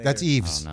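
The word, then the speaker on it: Yves: How do you say his name? Y-V. – I have no clue. – So that's Eves. – Oh, no. – Eves. That's what Eves.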